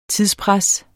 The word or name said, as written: TIDSPRES